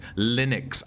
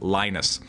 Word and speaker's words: Linux: The lie does not sound like line, it sounds like lint. Linux. I cringed every time I heard him say what sounded like Linus.